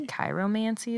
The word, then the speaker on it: chiromancy: It's also known not only as palmistry, but um, I think it's pronounced chiromancy. C H I R O. Chiromancy? – Maybe chiromancy is probably more accurate.